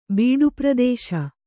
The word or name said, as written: ಬೀಡುಪ್ರದೇಶ